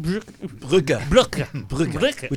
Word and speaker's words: Brücke: Now, how do you pronounce the last name? – Brücke. Which means bridge in German.